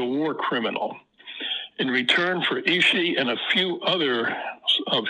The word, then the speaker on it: Ishii: Now, you mentioned Ishii, and that's spelled I-S-H-I-I. Ishii,